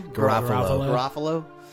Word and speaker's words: Garofalo: Garofalo.